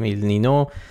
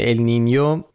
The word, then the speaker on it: ال نینیو: بله، به هر حال اینو همین اول بگیم که اگه دیدید یه بار میگیم ال نینو یه بار میگیم ال نینیو، علتش اینه، ولی تلفظ درست اگه میخواین ال نینیوه، ولی همه جا تو فارسی بچرخید کسی چیکی نمیفته، ال نینیو همه جا نوشتن ال نینو.